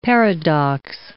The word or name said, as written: paradox